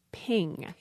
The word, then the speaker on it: PNG: And what I learned is we are not supposed to pronounce this PNG as I just did. It is ping. – Ping. – And the creators said, we do not want any multisyllabic disasters. It is pronounced ping.